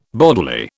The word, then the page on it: bodily: British English